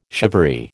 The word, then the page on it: shivaree: American English